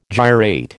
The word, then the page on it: gyrate: American English